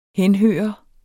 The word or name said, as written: henhøre